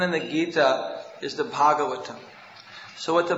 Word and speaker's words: Bhagavatam: And Bhagavatam, the long A, that means the song about Krishna, about the Supreme Person.